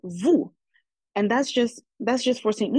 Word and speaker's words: vous: But however, in some other Creoles, they would use vous as well, but they would drop the S. In French, the vous is spelled V-O-U-S, but in the Creoles where they keep the vous, they would just drop the S.